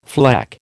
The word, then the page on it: fleck: American English